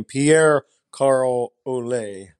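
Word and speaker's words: Pierre Carl Ouellet: Now, admittedly, I was still pretty young when, and I'm going to need to correct you on your pronunciation of Mr. PCO's name, Pierre Carl Ole, because he's Canadian.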